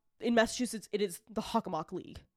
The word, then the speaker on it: Hockomock: Level of normalcy with which I pronounce it. – Very, like, this is the Hockomock.